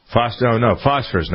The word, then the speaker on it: Phosphorus: Microsoft said that over the course of 30 days between August and September, a quote-unquote threat group named Philosophus. Is that how you pronounce that, you think? Phosphorus, not Philosophus. Sound like Mr. Snopinopagus.